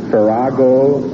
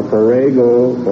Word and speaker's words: farrago: This may be pronounced either Farago or Farago or Farago. – Farago is spelled F-A-R-R-A-G-O. – Farago. – Farago. F-A-R-R-A-G-O.